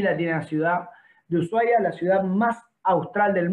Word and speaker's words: Ushuaia: Ushuaia o Ushuaia, como prefieren ustedes pronunciarlo, nosotros los argentinos la H la hacemos muda, así que le decimos Ushuaia, que es la ciudad del fin del mundo, es la capital de Tierra del Fuego.